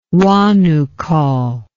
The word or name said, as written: Huanuco